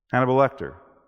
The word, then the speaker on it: Hannibal Lecter: Hannibal Lecter.